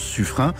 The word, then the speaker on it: Suffren: Notre journaliste a pu embarquer à bord du sous-marin nucléaire Suffren, les puristes prononcent Suffren, sous-marin dernière génération, 100 mètres de long, 5000 tonnes, 20 missiles à propulsion nucléaire.